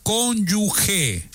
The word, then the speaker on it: cónyuge: No, señor presidente, no es cónyuge. Se pronuncia cónyuge. Je. No cónyuge. – Y no se está riendo al decir je. – No. Es cónyuge. Así se pronuncia.